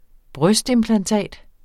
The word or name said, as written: brystimplantat